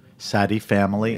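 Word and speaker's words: Sadie Family: S-A-D-I-E. – Sadi Family.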